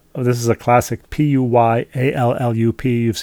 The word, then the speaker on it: Puyallup: P U P U Y A L L U P. Puyallup. Very good. Puyallup. Puyallup. Pew like P E W like like mm-hmm. someone sitting in their pew.